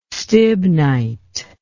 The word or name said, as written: stibnite